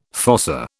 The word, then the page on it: fossa: British English